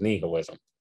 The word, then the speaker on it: nihilism: I think the proper pronunciation is nihilism, where people would have no sense of purpose, and that would become a very bad thing, and people would become morally repugnant.